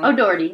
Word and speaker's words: O'Doherty: O'Doherty. I feel like I'm saying that wrong. – O'Doherty. That's right. Okay.